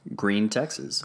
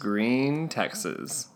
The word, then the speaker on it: Gruene: Green, Texas. – Green, Texas. – For everybody at home who doesn't know, Green is spelled G-R-U-E-N-E.